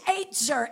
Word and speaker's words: ezer: So that word helper, the root word in Hebrew is ezer. And it's spelled E-Z-E-R. Ezer.